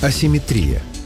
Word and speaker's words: асимметрия: Асимметрия. Слово, в котором ударение часто ставят по аналогии. Есть симметрия и получается асимметрия. Реклама.